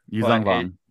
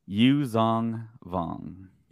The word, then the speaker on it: Yuuzhan Vong: Yuuzhan Vong. – Yes. Thank you. The, how do you say it? – Yuuzhan Vong.